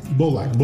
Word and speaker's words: Bolak: But Tommy Bolak. Bolak? Bolak. – Bolak. – Bolak. – Bolak. – Just like it's spelled.